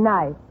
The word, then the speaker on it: knife: K-N-I-F-E. – K-N-I-F-E. That's Kniffy. – No, the word is knife. K is the silent letter. K is always silent in front of N.